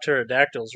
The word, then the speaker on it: pterodactyls: Well, you know what they say about pterodactyls, right? – Uh, no. – Uh, you can't hear them going to the bathroom because the P is silent.